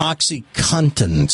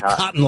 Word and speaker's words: OxyContin: Oxycontin. C-O-N-T-I-N. Oxycontin. Some people say cotton like a cotton ball. – Yeah. – Cotton. – Yeah. – There's an N in there.